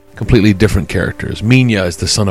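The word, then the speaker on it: Minilla: Now, it's spelled like Manila. Manila. But in the American dubs, it was always pronounced Minya.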